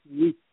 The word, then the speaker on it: Witt: That's R A U L M E-D-I-N-A. And my last name is Witt, W-I-T.